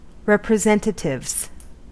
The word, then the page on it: representatives: American English